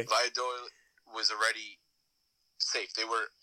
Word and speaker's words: Valladolid: I'm probably butchering this name, but Valladolid. – Oh, Valladolid.